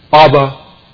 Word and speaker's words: Abba: Abba is an Aramaic term that has been transliterated from Aramaic into the Greek. It's spelled out phonetically for the Greek readers. Alpha, beta, beta, alpha, Abba. And then it's translated as the Father. Abba, the Father.